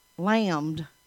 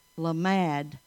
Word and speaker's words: Lamad: It looks like you would say lambed, doesn't it? That's not the way you say it. The Hebrew name is Lamad. L-A-M-A-D is how it's set out to pronounce. L-A-M-A-D, Lamad.